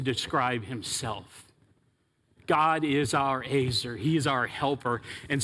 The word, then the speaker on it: ezer: But it's this word Azer, E-Z-E-R is the way we would spell it in English, Azer, E-Z-E-R,